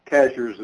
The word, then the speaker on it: Cashiers: Cashers is the way it's pronounced. Phonetically, it's spelled Cashiers.